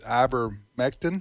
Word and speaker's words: ivermectin: ivermectin.